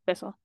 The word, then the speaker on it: Bissell: So it's Bissell is how you say it, right? – Bissell.